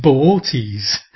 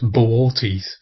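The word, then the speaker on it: Boötes: And Arcturus is actually part of a constellation called the Herdsman, which, if I get this pronunciation correct, I hope I do, is Bootes, which is B-O-O-T-E-S. So it looks like booties or boots. But apparently you're supposed to pronounce the two O's separately, so it's Boortes.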